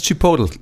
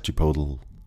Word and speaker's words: Chipotle: Heißt es Chipotle? Ich glaube schon, man muss es so ein bisschen verschlucken am Ende, sonst Chipotle. ist glaube ich nicht gut. Und was heißt es? Chipotle.